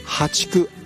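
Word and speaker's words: 淡竹: でこれからは破竹、淡い竹と書いてね、破竹なんですけどね、淡水の淡、淡いですね、これに竹、破竹。そして月末にはマダケといった種類が芽を出してくるということなんですね。破竹のタケノコというのは孟宗竹よりも細長く、アクやえぐみ、苦みが少なくて繊細で柔らかく、甘みがあって食べやすいと言われています。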